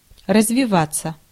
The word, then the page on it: develop: /dɪˈvɛləp/; American English